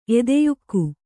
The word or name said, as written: ಎದೆಯುಕ್ಕು